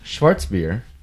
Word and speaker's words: Schwarzbier: The roots of the Schwarz, which is pronounced Schwarzbier correctly, the roots of the Schwarzbier lie in Thuringia and Saxony.